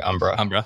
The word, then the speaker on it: Umbra: So you work at Phoenix, but you also write research at Umbra. Is that the right way to say it? – That's right, Umbra.